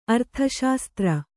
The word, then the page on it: ಅರ್ಥಶಾಸ್ತ್ರ: arthaśastra